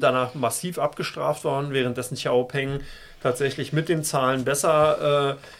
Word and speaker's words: XPeng: Li Auto und Xiaopeng tatsächlich in dieser Woche. Entschuldigung, habe ich nicht mal richtig ausgesprochen. – XPeng kannst du auch sagen. Das ist ja dann die amerikanische oder deutsche Variante.